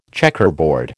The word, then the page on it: checkerboard: American English